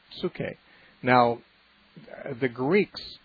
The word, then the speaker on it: psuche: And so when we have in our English translation the word soul in the New Testament, it's from the Greek word psuche, which is has a silent P at the beginning, P-S-U-C-H-E, psuche.